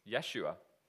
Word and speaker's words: Yeshua: But do you know what his Hebrew name was? Is? Yeshua, which is just the Hebrew pronunciation of Joshua.